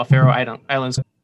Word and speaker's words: Faroe Islands: I just learned that my geography sucks, and wow, Faroe Islands. – But you actually pronounce it correctly.